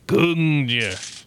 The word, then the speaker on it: Gungnir: Gungnir. So you have to say it like that. Gungnir. You need the N at first and then the Gungnir at the end.